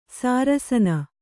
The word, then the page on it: ಶರಾಸನ: śarāsana